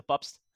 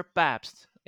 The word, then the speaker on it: Pabst: I'm pronouncing it in Dutch. I'm from the Netherlands, so Peter Pabst. – Awesome. And I'm so Americanizing it, Peter. – Yeah. And apologies for that. – Yeah, you have the beer with my last name as well. I'm always interested in how you pronounce it actually in English. – Pabst. – Pabst. Okay. Yeah. So I'm Peter Pabst in your language.